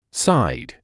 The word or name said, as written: side